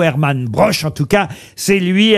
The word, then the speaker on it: Broch: Alors, je sais pas si on prononce Broer ou Broche. – Broche.